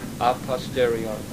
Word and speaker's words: a posteriori: A basic philosophical objection to all strict theistic proofs of the, how do you pronounce the Latin word? No, that's the other one. Posteriori? A posteriori.